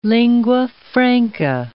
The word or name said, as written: lingua franca